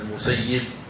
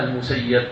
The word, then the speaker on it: المسيب: قال وحدثني عن مالك عن عطاء ابن عبد الله الخراساني عن سعيد بن المسيب، سعيد بن المسيب، سعيد المسيب يعني تنطق بكسر الياء مع تشديدها المسيب، وبعضهم ينطقها المسيب سعيد بن المسيب، لكن سعيد رحمة الله كان يكره الفتح، كان يكره الفتح، إذن فالأفصح أن تنطق سعيد بن المسيب عليه رحمة الله.